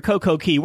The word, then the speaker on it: Coco Cay: It went to Nassau in their perfect day at Coco Cay. James, is it Coco Cay or Coco Key? What are you calling it? – I call it Coco Cay, but I hear it pronounced the both ways.